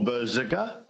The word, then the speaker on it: Berserker: Well, you say Berserker or Berserker, either, it doesn't really matter that much.